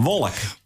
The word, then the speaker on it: Waalwijk: Maar een wolk. – Spreek je dat uit als een wolk? – Waalwijk. – Wolk. – Ja, dat noemen ze wolk.